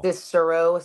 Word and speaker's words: Cicero: Cicero? It's outside of Chicago. – Cicero Stadium.